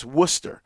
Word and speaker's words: Worcester: And, I flew here today, and it's one of those towns where it's not pronounced like it looks, like the way it's spelled, you know, because I've heard people say Worcester or Worcester, you know, but and it looks like that, like if you were to read, you know, how it's spelled, but it's Worcester, you know, that's how they say it here.